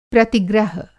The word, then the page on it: प्रतिग्रह: Hindi